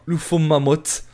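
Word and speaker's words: Ufomammut: Et donc si l'on veut bien prononcer le nom, il faudrait dire UFO mamotte.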